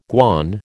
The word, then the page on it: guan: American English